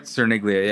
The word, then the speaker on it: Cerniglia: Cerniglia, yeah.